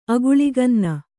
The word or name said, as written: ಅಗುಳಿಗನ್ನ